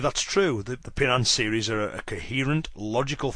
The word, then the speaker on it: Pinan: And the original Okinawan pronunciation for the first ideogram is pin, where the Japanese pronounce it he. So therefore that's where we get the two pronunciations of pinan and hian.